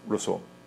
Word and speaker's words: Rousseau: Rousseau, gracias, Rousseau.